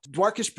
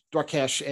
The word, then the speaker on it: Dwarkesh: And today we're going to talk about Dwarkesh Patel. I do not think I'm pronouncing his name right, by the way, but Dwarkesh, I think maybe is the right way to pronounce it, Patel, and his theory around the scaling hypothesis.